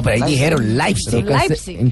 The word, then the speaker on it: Leipzig: Leipzig, Leipzig.